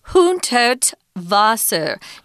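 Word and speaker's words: Hundertwasser: But it's Friedensreich, Hundert Wasser. Notice that the W in German is pronounced with a V sound. V.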